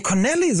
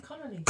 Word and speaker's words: Connolly: Nee, Connolly soll ich sagen, ich habe es falsch ausgesprochen. – Connolly.